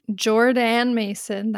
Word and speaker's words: Jordaan Mason: Jordaan Mason, that is how you say Jordan's name.